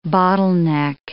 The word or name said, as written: bottleneck